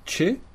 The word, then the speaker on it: ĉe: Donc l'espéranto dit che, ça s'écrit c chapeau et che.